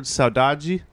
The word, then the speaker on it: saudade: You know this uh, this word saudade? Am I saying it right? Yeah. Mm-hmm.